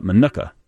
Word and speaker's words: Minooka: Well, I think I have some license to pronounce M-I-N-O-O-K-A any way I want. – Manuka? – It's Manuka. But the Manukites call it Manuka. There's Manuka and Manuka. Two O's, Manuka. – So the people who live there, you were admitting, say Manuka, but you say it the other way?